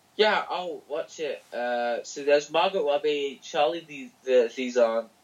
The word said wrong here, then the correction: Charlize Theron: Charlize Theron.